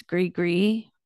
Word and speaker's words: gris-gris: And another part of voodoo is that they make these gris-gris, which, or maybe it's gris-gris, the S is silent in French, in New Orleans, which is these bags of herbs or magical substances.